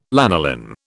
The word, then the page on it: lanolin: British English